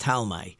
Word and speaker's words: Ptolemy: They were called the Ptolemy dynasty, even though they spell it a P-T, but it's as far as I know, it's pronounced in the Talmud, it's pronounced Ptolemy, but it's the Ptolemy dynasty.